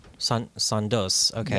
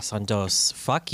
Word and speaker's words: Sondos: Sondol? – S-O-N-D-O-S. – Sondos. Okay. Yeah. Sondos. Fuck you.